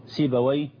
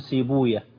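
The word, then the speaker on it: سيبويه: أهل اللغة ينطقونها كما أنطقها الآن، سيباوي باباوي نفطاوي بفتح الواو وإسكان الياء، وأهل الحديث لا ينطقونها كذلك، إنما ينطقونها يفرون من نطق وي، لأنهم يرون حديثا ضعيفا لا يثبت إسناده بأن وي اسم شيطان.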